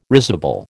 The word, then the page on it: risible: American English